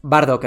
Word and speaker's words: Bardock: Badrock. – O Bakok, ¿cómo se dice? Bardock, el padre de Son Goku.